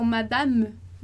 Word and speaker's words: madame: A não ser o E ali no final, então, madame, madame, madame, que vai ser, né? Mas você já sabe que e, que não é acentuado, não é pronunciado.